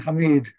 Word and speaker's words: Hamid: Karim Hamid. Karim Hamid.